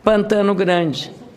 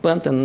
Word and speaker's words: Pantano Grande: Há uma cidade que se chama Pantano Grande. Pantano Grande. É assim que se escreveu, não tirou, não se colocou acento. Pântano, não. Se disser pântano, está errado.